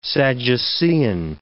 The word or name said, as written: Sadducean